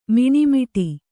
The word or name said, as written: ಮನೆಮುಟ್ಟು